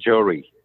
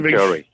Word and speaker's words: Johri: Just as you said it just right. It's just as it's spelled, Johri. – Okay, so it's Sandeep Johri, yeah.